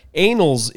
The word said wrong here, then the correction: annals: in that phrase means a chronological record of events. – Oh, anals. – Oh. – It's pronounced differently, apparently.